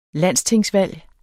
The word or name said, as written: landstingsvalg